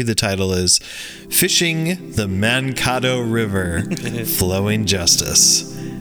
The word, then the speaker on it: Mankato: Mankato. Please pronounce it Mankato. like the city in Minnesota.